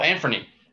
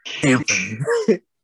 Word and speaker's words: Anfernee: Oh, uh, oh, Anfernee. Yeah, there we go. Anfernee.